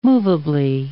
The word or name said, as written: movably